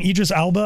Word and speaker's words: Idris Elba: Idris Elba, I think.